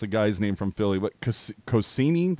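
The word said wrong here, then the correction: Konecny: that's what it looks like it is, but how do you pronounce it? – Konechny.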